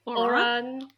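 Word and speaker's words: Ouran: Yeah, and then we have Oran, is that how you pronounce it? – Oran, yeah, whatever.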